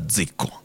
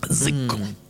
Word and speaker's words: dziko: How do you spell that ziko? – D-Z-I-K-O. – But the D is like the G in lasagna. You have to know ziko. – Ziko.